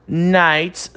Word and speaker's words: knights: Knights são cavaleiros, beleza? Repara que o K é mudo, ele não tem pronunciamento. Knights, ok?